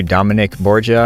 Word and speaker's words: Dominick Borgia: In this episode, I finally get to sit down and interview Dominick Borgia.